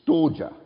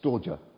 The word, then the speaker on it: storge: The first one is called storge. That's perhaps a Greek scholar would say it doesn't pronounce like that, but S-T-O-R-G-E, right?